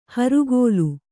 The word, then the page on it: ಹರಿಗೋಲು: harigōlu; Kannada